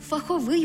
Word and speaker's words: фаховий: Отже, який фаховий виступ, яка фахова думка, чи, до прикладу, цікавий і фаховий журнал. Але аж ніяк не фаховий, аж ніяк не фахове.